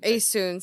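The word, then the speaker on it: Essun: I mean, so I a woman, Isun, right? – Aesun, sorry. – No, it's fine. That I only know that because that's how the narrator pronounced it.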